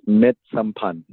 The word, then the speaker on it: Misamphan: It's pronounced Misamphan. – Misamphan. All right. So am I doing well? – The last syllable is, that H is silent.